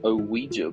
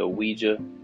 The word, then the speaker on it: Ouija: Let's check out some strange and mysterious history of the Ouija board. Ouija. O-U-I-J-A.